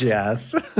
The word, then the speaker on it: Gorgias: A famous one was, I think it's Gorgias is how you pronounce his name? Or is it Gorgias? – Gorgias. – Right. I think it's actually Gorgias.